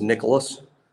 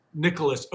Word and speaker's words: Nyckoles: There is a kid named, is it Nichols Harbor? Is that how you say it? – I think it's Nicholas. – Nicholas. Okay, but it's spelled N-Y-C-K-O-L-E-S. – Harbor. Yeah, I think he pronounces it Nicholas.